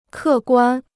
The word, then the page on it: 客观: kè guān